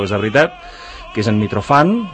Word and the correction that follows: Mitrofan: ho pronuncio bé, Mitrofan? – No, Mitrafan.